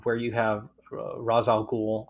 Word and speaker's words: Ra's al Ghul: Ra's al Ghul. – I agree totally. – And if we're basing it on an Arabic character, Ra's is the more traditional Arabic pronunciation from my experience.